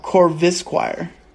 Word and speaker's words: Corvisquire: Corvesquire. No, Corvisquire. That's his name.